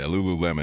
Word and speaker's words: Lululemon: Like, my wife lost her Lululemon, however you pronounce it, Lululemon, whatever, gift card one time. – Yeah, Lululemon.